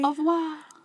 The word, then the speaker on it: au revoir: If you say the R's, you're an idiot. – Au revoir. – Au revoir. – Au revoir. – It is two syllables.